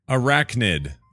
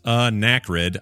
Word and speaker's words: arachnid: Scott, the word is arachnid. What did I say? Say it with arachnid. Every time I hear you pronounce anachrid, I don't say anachrid. I always say arachnid.